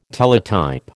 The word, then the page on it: Teletype: American English